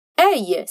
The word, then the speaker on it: œil: Bom, œil se escreve O-E-I-L. Aqui, encontramos duas vogais juntas que, no fim, viram uma vogal só, que é o O.